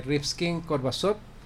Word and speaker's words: Rimsky-Korsakov: Y bueno, nada, la invitación para nuestros oyentes es para que asistan al concierto especial Redden Bus de Rimsky-Korsakov, Kim y Rachmaninoff.